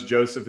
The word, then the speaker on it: Joseph: Everybody knows Joseph is J-O-S-E-P-H. And then it's M-I-C, and then it's hell, I. So M-I-C, hell, I will get you there.